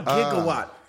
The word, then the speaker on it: gigawatt: So when it was actually a lot of people are surprised to find out that it's not JIGG, it's GI, it's a gigawatt. – It's a gigawatt.